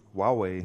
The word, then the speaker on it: Huawei: Oder richtig spricht man Netz, die ganz irgendwie Huawei aus. – Wie? – Huawei. – Ja, so ähnlich.